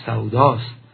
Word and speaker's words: سودا: سودا، تلفظ درستش سوداست، یعنی داد و ستد، خرید و فروش.